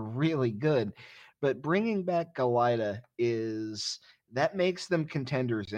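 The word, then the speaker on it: Galajda: who brings back sophomore goalie Matthew Galita, who, apologies, we had to spend most of last season figuring out how to pronounce his name, but it is Galita, and he is maybe the best goalie in the East, maybe the best goalie in the country.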